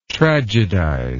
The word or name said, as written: tragedize